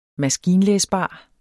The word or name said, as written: maskinlæsbar